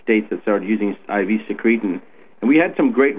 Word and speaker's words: secretin: For example, secretin, or sometimes pronounced as secretin, was a substance that was being injected into children around the mid-1990s, late 1990s.